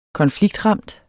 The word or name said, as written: konfliktramt